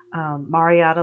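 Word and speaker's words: Marriotta: Marietta, like the hotel, add an A, Gary Smith.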